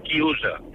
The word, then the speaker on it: chiusa: Y un momento, y quiero decirle a Fernando que en italiano se dice chiusa. Coro a boca chiusa. Sí, no chiusa.